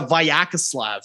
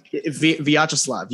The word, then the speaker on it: Vyacheslav: For example, you look at Arthur Akhtiamov and Vyacheslav. – Peksa. – It's Peksa. It's the Vyacheslav. – Oh, Vyacheslav. – Vyacheslav. Yes. Vyacheslav Peksa.